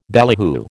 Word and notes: ballyhoo: American English

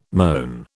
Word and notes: moan: British English

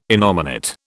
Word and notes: innominate: British English

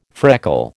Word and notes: freckle: American English

